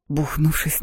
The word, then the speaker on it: Бухнувшись: Бухнувшись на кровать, ударение на второе «у», я из последних сил надела маску луны и даже не сняла кроссовок.